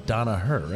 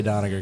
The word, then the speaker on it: Donaher: It should be pronounced like Donaher, right? Like Donaher? – That's it. – Right? – Yeah. – But we all say Doniger because we're an American, you're a Republican in Queens.